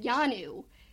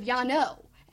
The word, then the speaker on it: Yano: Also, I've always said Yanu instead of Yano.